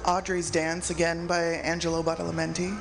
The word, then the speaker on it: Badalamenti: We started that off with the Twin Peaks theme by Angelo Badalamenti. – And I let Claudia pronounce that because... – Badalamenti.